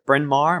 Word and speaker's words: Bryn Mawr: As you said, you're away up in Baltimore at uh, Bryn Mawr. Am I mm-hmm. saying that right? That's it.